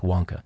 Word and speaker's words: Vanke: The last and greatest of the developers, it's spelled Vanke, but pronounced Wonka, is coming under a fresh round of financial pressure.